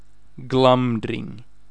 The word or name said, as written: Glamdring